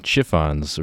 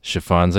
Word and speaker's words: Chiffons: I, I, was saying chiffons originally. It's chiffons, I think. A caller came in to uh, correct that.